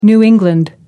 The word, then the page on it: new england: American English